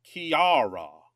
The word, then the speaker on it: Ceara: Is it Kira? C-E-A-R-A. Is that how you pronounce it? – Kiara. – Kiara? – Kiara. I'm probably still mangling that, but...